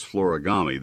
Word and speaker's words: Florigami: It's been interesting on Florigami to hear the newscasters, when they're handed this news release, try to pronounce Florigami. They don't seem to realize that it's like origami, just with an FL at the beginning.